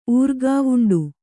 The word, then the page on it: ಊರ್ಗಾವುಂಡು: Kannada